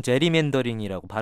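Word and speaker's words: gerrymandering: 예, 자 하원의원 선거구라면 이른바 이 게리맨더링하고 관련이 있겠군요. – 맞습니다. 미국인들이 보통 제리맨더링이라고 발음하는데요.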